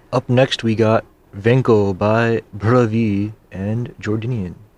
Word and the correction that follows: Jordindian: Jordindian, sorry. That's a little difficult to pronounce.